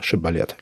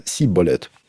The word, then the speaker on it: шибболет: В Библии описан эпизод, где воины на переправе просили каждого произнести слово «шибболет». Люди с другого племени не могли выговорить звук «ш» и говорили «сиболет».